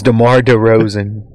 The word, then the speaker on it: DeRozan: Like I said, name's DeMar DeRozan. Am I saying that right? – You are. Okay.